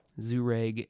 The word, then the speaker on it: Jhereg: The first three books are in a volume called The Book of Zureg. That's how that's pronounced there for you, for those of you watching on the YouTubes. I don't know if that's really, the camera's really focusing on that, but Zureg, J-H-E-R-E-G.